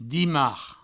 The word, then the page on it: dimarc: dimars; Provençal